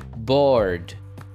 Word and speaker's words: bored: Veja como eu pronuncio o ED no final da palavra. Bored. Bored. Aqui, como vocês podem perceber, a letra E é muda. Então, esqueça de pronunciar ela.